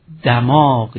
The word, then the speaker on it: دماغ: و گفتیم که دماغ در مجموع 7 بار در دیوان خواجه به کار رفته، منتها 1 بار دماغ، یعنی این واژه رو حتما به فتحه نخست باید تلفظ کنیم، که اینها رو به تفصیل عرض کردیم.